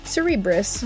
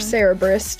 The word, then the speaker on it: Cerberus: The first one that springs to my mind, personally, is Greek mythology's Cerberus, mm-hmm. or Cerberus, depending on where you like to put the emphasis on which syllable. So, you know, do it your own way. I've heard it both ways.